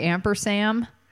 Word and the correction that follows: ampersand: Did I say it wrong? – Sand. – Yeah. – And I said it wrong. I said Ampersam. – It's my favorite bit of typography. Yeah. So I just wanted to make sure you said it correctly.